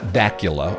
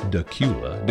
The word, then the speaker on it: Dacula: It's not Dacula, although it's spelled that way. It's Dacula.